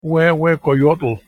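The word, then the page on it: huehuecoyotl: Nahuatl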